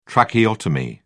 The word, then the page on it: tracheotomy: British English